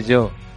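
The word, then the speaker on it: Giò: Me acabas de decir Gio, ¿no? – Gio. – No se dice Gio, se dice yo.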